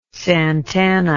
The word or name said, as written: Santana